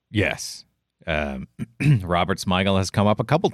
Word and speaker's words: Smigel: But my biggest surprise was Robert Smigel. Is that how you say it? – Yep.